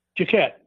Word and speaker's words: Jacquet: how do you say his name Jacquet Jacquet yeah Jacquet illinois